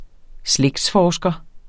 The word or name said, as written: slægtsforsker